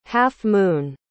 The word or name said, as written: half-moon